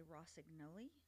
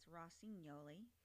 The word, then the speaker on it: Rosignoli: Well, if you're from Italy, you say Rosignoli. If you're from America, it's Rosignoli.